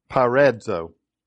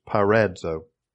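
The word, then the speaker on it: peirazō: Now the word tempted there is the Hebrew word, and here we'll try it, parazo. And that is the pronunciation, that's not the spelling, it's a P-E-I-R-A-D-Z-O, I believe. But the pronunciation in the Hebrew is parazo.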